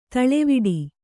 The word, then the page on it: ದೂಳುವಡೆ: dūḷuvaḍe